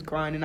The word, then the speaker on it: Grande: Her name is Grande, not Grande. A lot of people say her last name wrong.